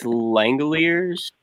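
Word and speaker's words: Langeliers: I don't think defensively he's as good as a guy like Shea Langille. I don't even know how to pronounce this guy's name. Do you mind helping me out with his last name? – I think it's Langilliers.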